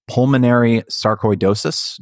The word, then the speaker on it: pulmonary sarcoidosis: pulmonary sarcoidosis.